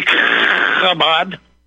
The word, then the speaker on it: Chabad: We have to make sure that you pronounce that right. You cannot forget the sound when you say Chabad.